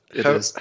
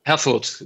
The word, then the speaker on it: Herfurth: Herfurth. – Herfurth. – Herfurth, yeah, just forget about the last H and you're fine.